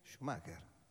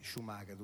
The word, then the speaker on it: Schumacher: Primo, Michael Schumacher. Schumacher. E adesso te lo dicono come dire fesso, vedi che non sai pronunciare. E l'abbiamo detto per 8 anni noi, Schumacher tutti.